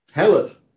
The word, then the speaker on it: Hallett: And the names of the town, first syllable H-A-L, second syllable L-E-T-T. Hallett.